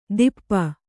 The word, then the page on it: ತಿಪ್ಪ: tippa